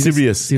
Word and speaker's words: Sirius: I don't know how to pronounce it. – Sirius. S-I-R-I-U-S.